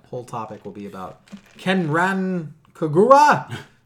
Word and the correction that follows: Senran Kagura: No, it's Senran Kagura.